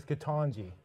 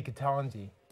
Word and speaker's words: Ketanji: I'm being told by a medical doctor it's Ketanji. – Yeah. – Which they say phonetically, but like in Canada, we would say Ketanji, like taco or pasta.